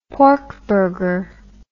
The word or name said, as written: porkburger